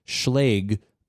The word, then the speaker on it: Schlage: pronounced Schlage like plague.